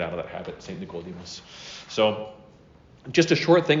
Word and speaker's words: Nicodemus: Saint Nicodemus is the Greek pronunciation. It's what I'm used to. Nicodemus is how we would normally say it in the West.